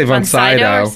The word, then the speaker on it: Von Sydow: Von Sydow. – How do you pronounce it? – Oh, I say Von Sydow.